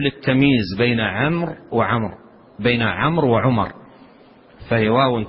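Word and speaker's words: عمرو: فيما يتعلق بعمر يكتب في آخرها واو عمر، والواو لا تنطق وإنما تكتب دون نطق،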